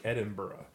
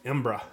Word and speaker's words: Edinburgh: And that's how you say it. I was saying Edinburgh. And right? – It's not. – I know. That's how you would read it. – Edinburgh. And our tour guide says, don't even say it like Edinburgh. Say it like Embra.